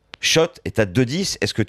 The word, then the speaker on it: Kszczot: Alors, je le prononce Schott parce que je me suis renseigné, mais c'est bien au Scrabble. Hein. K-S-Z-C-Z-O-T.